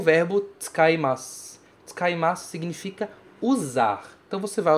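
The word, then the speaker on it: Tsukaimasu, tsukaimasu (使います): Tsukaimasu também tem uma mudancinha na pronúncia, porque você fala tsuka, tsuka. Esse U é praticamente mudo, praticamente não tem.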